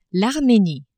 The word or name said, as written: Armenie